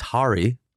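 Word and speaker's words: Harri: So I asked his agent today, and we are, in fact, butchering his name. All three of us have been butchering his name. But what do we know? We got bad advice. – Classic. – So first of all, it's Harry.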